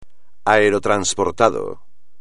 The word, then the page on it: aerotransportado: espagnol